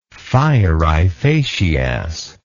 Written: fieri facias

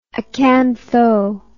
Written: acantho

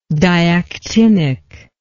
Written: diactinic

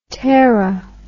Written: tera

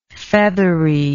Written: featheriness

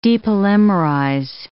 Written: depolymerize

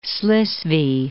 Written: Slesvig

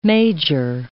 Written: major

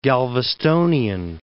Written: Galvestonian

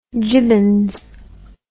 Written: jibbons

